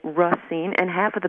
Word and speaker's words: Racine: I'm from Racine. Some people say Racine, others say Racine. I say Racine, even though I speak French and know the correct pronunciation is Racine.